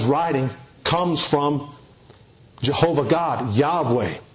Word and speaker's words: Yahweh: YHWH, pronounced Yahweh.